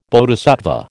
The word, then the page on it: Bodhisattva: American English